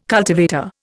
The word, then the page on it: cultivator: Indian